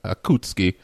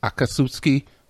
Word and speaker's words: Akatsuki: I dressed up as Sasuke Uchiha, as a part of the Akutsuki. – Yeah, Akatsuki.